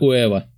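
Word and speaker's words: Huevo: Хуева Гранде. Неправильно прочитал. В смысле? В испанском не читается первая буква А. Уева? Да, это слово читается как Уэва.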